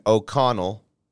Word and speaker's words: O'Connell: Daboll took over and O'Connell, did I say it right or did I say it wrong again? – You said it right.